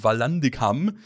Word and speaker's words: Vallandigham: Ja, er orientiert sich an dem Fall des amerikanischen Politikers Clement Valindicam, das ist auch ein schöner Name, also ich sag mal ausgesprochen Vallandigham, der auch als Rechtsanwalt tätig war.